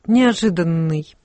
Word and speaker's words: неожиданный: Как надо сказать, неожиданный или неожиданный? – Вот неожиданный, конечно, с одним «Н», потому что далеко от ударения.